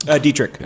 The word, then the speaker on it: Dietrich: You're listening to Shift Drink with myself, Eddie Kim, and Chris Hanna and Nick Dietrich. Am I saying that right? – Dietrich. – Okay, Dietrich, sorry. – It reads like get richer or debt rich or die trying, but from New Orleans, they're in town for the night tonight to do a little pop up over at Tail Up Goat.